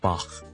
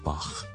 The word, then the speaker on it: Bach: 如果英文化嗰个咧，我哋叫 Bach，B-A-C-H。第二一个比较接近佢原文嘅发音咧，我哋系读 Bach。用一个喉咙嘅一个轻微嘅摩擦音，voiceless 嘅声音，我哋叫 Bach。